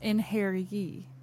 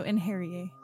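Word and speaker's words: Einherjar: It is pronounced Einherji because it's like the Viking J word, like a huh sound. So, Einherji.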